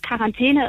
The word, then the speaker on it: Quarantäne: Und weil man aber im Französischen QU generell immer nur mit K ausspricht, wurde zwar das Wort Quarantäne im Laufe der Zeit orthografisch, also von der Rechtschreibung im Deutschen angepasst, aber die Aussprache mit K aus dem Französischen, die ist beibehalten worden.